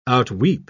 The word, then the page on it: outweep: British English